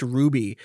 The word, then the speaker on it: RWBY: I mean, that was so like go if you ever go back and watch Ruby, which is spelled RWBY,